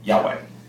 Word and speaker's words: Yahweh: the most contemporary sort of English pronunciation of it is this. – Yahweh. – It was also rendered, particularly through Latin translations, because of the way that the sounds of the letters work differently.